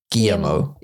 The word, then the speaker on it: Guillermo: Guillermo, even though it's got L's in it.